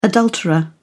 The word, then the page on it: adulterer: British English